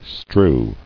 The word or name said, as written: Strewing